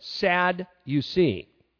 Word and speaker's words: Sadducees: Now here's a good way to remember Sadducees. Pronounce it this way, sad you see.